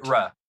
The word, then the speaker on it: Moira: Moira. I can't say it right now. – Moira. – McTaggart. – Yeah. M-O-I-R-A.